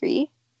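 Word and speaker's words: gris-gris: I think what you're saying is not pronounced grease. I think it's gree. I don't think you pronounce the S, because I think I know what you're talking about.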